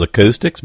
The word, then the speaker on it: Acoustix: Acoustics, a group that call themselves Acoustics before that, and they spell it A-C-O-U-S-T-I-X.